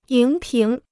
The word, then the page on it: 荧屏: yíng píng